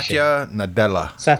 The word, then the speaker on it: Satya Nadella: Satya Nadella.